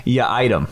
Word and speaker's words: Yiadom: From here's the pronunciation guide from Boston College. Isaac Yaitum your item.